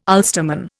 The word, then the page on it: Ulsterman: Indian